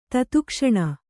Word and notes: ತತ್ಕ್ಷಣ: Kannada; tatkṣaṇa

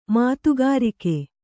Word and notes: ಮಾತುಗಾರಿಕೆ: mātugārike

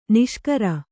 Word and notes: ನಿಶಿಕರ: Kannada

